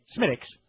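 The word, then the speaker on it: Smithwick's: Dude, the best beer I've ever had, it's spelled American Smithwicks, but as an Irish person, we say Smittix.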